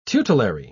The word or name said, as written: tutelary